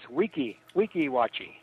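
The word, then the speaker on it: Weeki Wachee: Actually, Joe, it's Wichiwachi.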